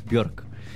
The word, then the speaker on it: Björk: This next song I'm going to play is from the iconic Björk, which is the correct way to say it in Icelandic language.